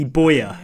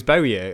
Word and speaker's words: Bowyer: Lee Bowyer was pronounced Lee Boyer. – Yeah. – Well, the thing is that Leeds fans still, I think, call him Boyer, which is really weird. I've never really figured out the pronunciation because I was always Bowyer as a kid.